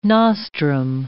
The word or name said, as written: nostrum